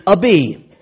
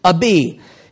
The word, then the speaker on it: Abi: It says, verse 2, and his mother's name also, that's pronounced Abi, Abi.